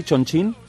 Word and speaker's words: Chongqing: ¿Se dice así, Chongqing? – Chongqing, sí.